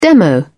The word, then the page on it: demo: British English